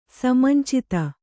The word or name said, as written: ಸಮಂಚಿತ